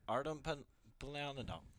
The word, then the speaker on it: Artemi Panarin: Artem Panarin.